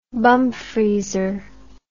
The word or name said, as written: bumfreezer